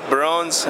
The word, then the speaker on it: Barons: Les Barons, right? How do you say that? Barons? – Baron.